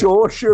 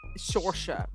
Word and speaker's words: Saoirse: I refuse to ever pronounce it right. – Saoirse Ronan. – Saoirse, right.